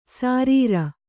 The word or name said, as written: ಸಾರೀರ